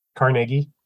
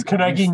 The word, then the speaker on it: Carnegie: Carnegie. It's Carnegie Museum of Natural History. It's not Carnegie, which is the New York deli. It's Carnegie in Pittsburgh.